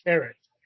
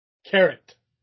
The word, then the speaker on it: Karryt: Jimmy Carrot. – Yes. – Carrot. Like the vegetable. – It's spelled K-Y something. Yes. – Okay, perfect. Yeah, I can definitely look him up from that. – Fucking Christ, dude. Look at you. This show sometimes. – Look up Jimmy Carrot. It's spelled K-Y. – Nobody said I'm that smart. Okay. Jimmy Carrot. K-A-R-R-Y-T. – Okay, so it's like the vegetable.